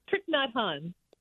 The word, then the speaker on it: Thich Nhat Hanh: It's like Thich Nhat Hanh. I can't pronounce it. – Thich Nhat Hanh. – Thich Nhat. Okay, thank you.